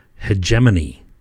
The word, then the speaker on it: hegemony: tyrannical hegemony. Hegemony? Hegemony. I can never say that word. Hegemony. Hegemony. I think hegemony sounds better. As people sometimes say with a hard G, hegemony, which sounds even worse.